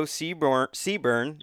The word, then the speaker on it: Seaburn: I got Joe Seaburn.